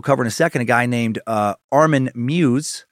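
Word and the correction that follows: Meiwes: or I think it's I had their pronunciation here in a second. Mivas, there we go.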